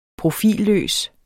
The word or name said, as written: profilløs